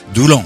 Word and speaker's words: Doullens: À Doullens, donc, Doullens, on doit dire. Ah oui, on prononce pas le S, hein.